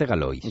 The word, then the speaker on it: Galois: venga, a ver, que seguro que este, no sé ni cómo se pronuncia, Évariste Galois.